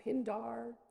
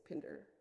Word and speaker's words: Pindar: I, as an American-born classicist, would probably say Pindar. But clearly, if you're an aristocrat who went to Harrow, the correct pronunciation is Pinder.